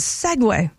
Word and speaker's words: segue: But yeah, S-E-G-U-E. You say that as Segway.